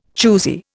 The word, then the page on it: choosy: Indian